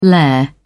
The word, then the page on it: lair: British English